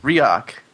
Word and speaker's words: Riak: React. – There you go. I pronounce it incorrectly every time. It's spelled R-I-A-K, right? – R-I-A-K.